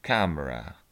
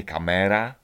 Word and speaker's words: camera: Wymawiamy to camera. Camera. Nie kamera, tylko camera. Pamiętajcie o tym. No raz utalentowany.